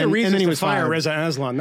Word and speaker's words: Aslan: That was just before Aslan. Is the way we pronounce it. – Reza Aslan.